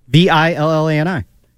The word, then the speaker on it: Villani: Villani. It's like villain.